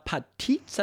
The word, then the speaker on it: Patitz: and Tatiana Patitz, I believe is how you say her name.